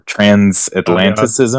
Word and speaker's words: Transatlanticism: And then the other one is Transatlanticism or Transatlanticism. Oh, yeah. Death Cab for Cutie. I think I'm saying that right. – Yep.